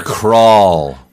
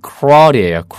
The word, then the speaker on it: crawl: Crawl. – 맞습니다. C-R-A-W-L인데 발음이 crawl이에요. Crawl.